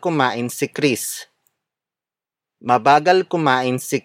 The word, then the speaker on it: kumain: Mabagal kumain si Chris.